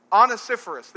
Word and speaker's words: Onesiphorus: Onesiphorus.